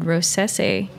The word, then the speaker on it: Rossese: There's a grape variety called Rosese. – Spell it. – R-O-S-S-E-S-E. – Okay. Rosese is the grape? – Yes.